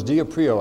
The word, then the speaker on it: diaprio: The word is diaprio. I'll spell it for you. D-I-A-P-R-I-O. Diaprio.